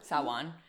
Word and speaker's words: Samhain: Sa- Sa- Sawan. Sawan. – It's spelled S A M H A N. So I I don't know how it's Sawan, but I'm just gonna go with that.